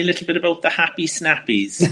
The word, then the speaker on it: Happy Snappies: So the first thing you need to know is that happy snappies rhymes with nappies.